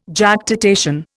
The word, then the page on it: Jactitation: Indian